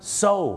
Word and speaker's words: sew: โซ